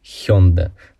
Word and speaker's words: Hyundai: Но правильно все-таки говорить Хёнда, с ударением на последний слог.